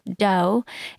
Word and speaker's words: Deux: Sabeena, Ladha is the CEO and founder of Dough. It's spelled D-E-U-X.